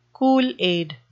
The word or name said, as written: kool-aid